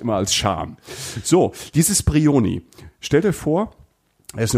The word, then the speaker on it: Brioni: Die Insel heißt Brioni. Und es gibt noch, das ist die, die italienische Aussprache,